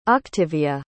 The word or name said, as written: octivia